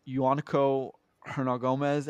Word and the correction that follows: Juancho Hernangómez: I did not say that right, and I'm sorry. – It's Juancho Hernangómez.